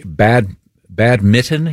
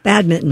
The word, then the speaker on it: badminton: No, we were talking about, I'm going to pronounce this incorrectly, badminton. You don't pronounce that N? – You do not. – No, badminton.